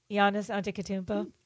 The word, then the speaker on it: Giannis Antetokounmpo: Oh, Giannis Antetokounmpo.